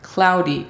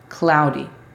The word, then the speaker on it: cloudy: La pronunciación fonética es C-L-A-U-D. Cloud. Después tenemos cloudy, que también es un estado climático. – Nublado. – La pronunciación fonética de esta es C-L-A-U-D-I. Cloudy. La D es casi una R.